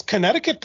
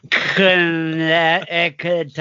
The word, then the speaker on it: Connecticut: And our special guest star once again, a man who can pronounce Connecticut properly, our special guest. – Connecticut. – Isn't it Connecticut? – No. – Connecticut? – That's how it's spelled, but spelling doesn't matter.